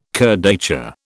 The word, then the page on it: kurdaitcha: British English